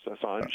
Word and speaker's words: Assange: Is that how you pronounce it? – Yes, Assange.